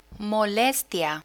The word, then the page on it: molestia: Spanish